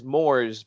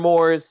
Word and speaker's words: Moores: Mr. Moores sounds like s'mores.